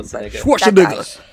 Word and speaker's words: Schwarzenegger: Schwarzenegger.